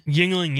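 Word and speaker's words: Yuengling: Recently, however, I've discovered, I don't even know how to say this, Yingling? – Yingling, yeah.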